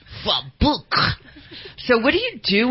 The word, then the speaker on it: Frau Blücher: Frau Blücher.